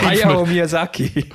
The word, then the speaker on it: Hayao Miyazaki: Hat man wahrscheinlich, meinst du ein bisschen so, der, ja, es ist vom Hayao Miyazaki. Sorry, du hast es besser können aussprechen. – Ja, der. – Hayao Miyazaki.